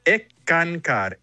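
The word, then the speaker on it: Eckankar: Se pronuncia Ekankar, Sekankar, significa ENCCAR.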